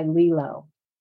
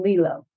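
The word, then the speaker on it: Lelo: Well, I, like you said, everybody's body's different, but my favorite toys are by Lilo.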